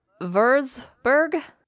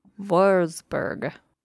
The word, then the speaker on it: Würzburg: She enrolled at a University of Würzburg. That's a W, so it's pronounced with a V. – Very good. – Würzburg.